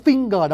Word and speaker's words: finger: Chữ finger đọc là fin xong là G, NJNG.